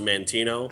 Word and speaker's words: Montana: Well, then we will schedule a trip to Montana to, how are you supposed to say it? Montano? – I always pronounce it Montana.